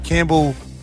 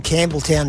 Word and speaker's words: Campbelltown: Can you explain to him Campbelltown is not two syllables? – It's just Campbell.